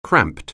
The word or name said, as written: cramped